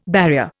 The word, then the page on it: barrier: Indian